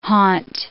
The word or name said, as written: haunt